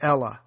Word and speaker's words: Helle: the Ella, spelled H-E-L-L-E, the H is silent.